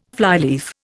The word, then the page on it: flyleaf: Indian